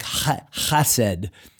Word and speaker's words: chesed: The word loving kindness here is the very famous Hebrew word hesed or chesed. It's hard to pronounce. The Hebrew language has a little bit of a guttural, like chesed.